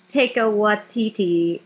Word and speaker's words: Taika Waititi: I'll take the Taika Waititi.